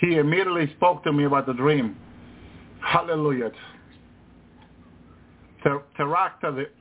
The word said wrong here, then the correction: pterodactyl: Teractatil. Teractatil. I can't pronounce that word. Mm-hmm. What, what those flying birds are called. – Ah, yeah, they're from the past. – Pterodactyls. – pterodactyl, yeah, pterodactyls.